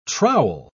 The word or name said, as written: trowel